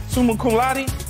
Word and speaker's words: summa cum laude: Summa cum laude. Did I say that right? – Yeah. – Summa cum laude.